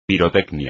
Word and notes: pirotecnia: espagnol

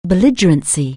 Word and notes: belligerence: anglais

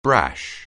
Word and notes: brash: anglais

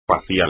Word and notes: facial: espagnol

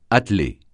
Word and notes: atteler: francais

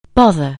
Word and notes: bother: anglais

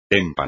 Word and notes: témpano: espagnol

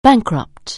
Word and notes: bankrupt: anglais

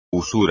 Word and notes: usura: espagnol

